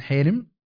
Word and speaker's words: حارم: فهي عموما حارم هذه قلعه مركزيه، ودائما دائما محل ها ايه تمام، يعني تنطق الان حيرم، هذا ايش اللهجه الحلبيه ولا لادلب حيرم.